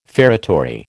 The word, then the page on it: feretory: American English